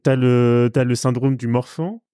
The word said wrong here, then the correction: Marfan: De, Marfan, oui, pardon.